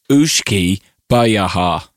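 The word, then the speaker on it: uisce beatha: And the first one is spelled U-I-S-C-E, and the second one is spelled B-E-A-T-H-A. So I would say that's Ooshkabar or Ooshkibita. – No, it's Ooshkabar. – All right. Well, yeah. It says here it's pronounced Ooshki Bayaha.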